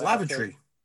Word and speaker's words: Lavatory: Lavoratory Lil, I had to make sure I pronounced that because I keep saying laboratory. Lavoratory Lil, studio it's lavatory.